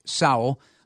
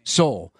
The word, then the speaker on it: Sowell: So Bradley Sowell, and that's how his name is going to be pronounced from now on, like Powell, it's Sowell. Mark Rohde tipped me off to that. Bradley saying, fellas, his stage name was Soul. Everybody was calling him Soul, but it actually is Sal.